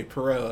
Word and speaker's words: Poirot: did need other people to go Poirot. Oh, okay, Poirot. That's how you say it.